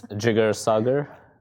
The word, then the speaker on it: Jigar Sagar: It's Jigar Sagar.